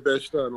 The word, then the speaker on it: Bed-Stuy: And so you're from Bed-Stuy, right? I don't know if I even said that right. Bed-Stuy, is that how you say it? – Bed-Stuy, no doubt.